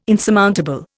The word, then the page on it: insurmountable: Indian